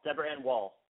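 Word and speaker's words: Deborah Ann Woll: I just also want to let people know, though, one of my favorite girls from True Blood, Deborah, how do you say her name? Deborah Ann Wall? – Wall. Deborah Ann Wall.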